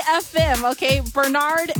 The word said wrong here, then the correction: Barend: and Beener. – Barend and Benner. – Barnard and Benner. – Barend and Benner. – Thank you.